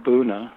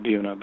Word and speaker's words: Buna: They produced a nitrile rubber, which they called a Buna. We call it Buna, but I guess they pronounce it Buna, B-U-N-A.